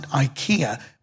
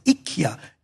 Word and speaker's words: IKEA: In England, we pronounce it IKEA, but in Sweden, they pronounce it IKEA.